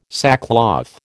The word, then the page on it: sackcloth: American English